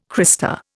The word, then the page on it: crista: Indian